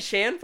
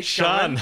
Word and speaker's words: Sean: You don't say Shan, Freddie? You say Sean.